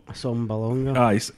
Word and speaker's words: Asamoah: like Britt Asamoah, I think, how do you pronounce that? – Asamoah.